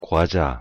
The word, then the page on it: 과자: Korean